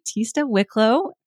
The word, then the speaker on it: Tista Wicklow: I'm joined here by Tista Wicklow.